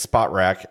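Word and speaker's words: Spotrac: Because I DM'd him once and said, hi, we talk about your website a lot, so how do we say it correctly? And he said, it's Spot Rack, and it's terrible.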